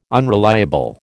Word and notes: unreliable: American English

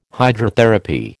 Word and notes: Hydrotherapy: American English

